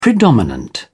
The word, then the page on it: predominant: British English